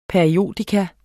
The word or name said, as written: periodikum